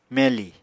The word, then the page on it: meli: Apatani